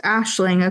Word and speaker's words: Aisling: I figured with the name, because it's pronounced Aisling according to the audiobook, but it's A-I-S-L-I-N-G. So that's like some sort of Gaelic for sure.